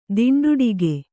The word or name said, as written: ತುಂಡಾಡಿಗ